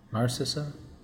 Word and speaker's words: Narcissa: Who is Narcissa? Did I say that right? – Yeah, so, Narcissa is Draco's mom, who is also Sirius Black's first cousin.